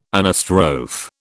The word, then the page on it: anastrophe: British English